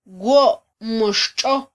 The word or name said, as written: wo mo xtxo